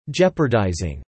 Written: jeopardizing

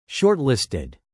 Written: shortlisted

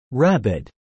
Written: rabid

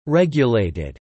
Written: regulated

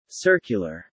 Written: circular

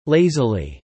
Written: lazily

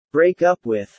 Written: break up with

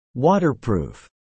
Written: waterproof

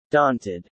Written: daunted

